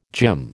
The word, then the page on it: gym: American English